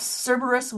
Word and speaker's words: Cerberus: Yes, Cerberus one head less.